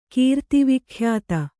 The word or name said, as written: ಕೀರ್ತಿವಿಖ್ಯಾತೆ